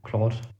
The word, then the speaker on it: Claude: Also man schreibt es C-L-A-U-D-E. Claude 3. – Claude ist es doch. Also der französisch ausgesprochen.